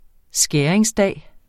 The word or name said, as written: skæringsdag